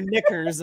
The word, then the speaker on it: knickers: And like, you know, they're wearing their knickers and they're like, whoa, my dad Scott, careful. – The dive. Yeah, you heard me. I said knickers. Knickers. – Silent K.